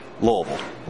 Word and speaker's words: Louisville: And it's not, you got to pronounce it like you got a mouthful of marbles, Louisville.